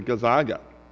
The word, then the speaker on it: Gonzaga: So I was imprecatory towards the Gonzaga, or whatever, however you say it.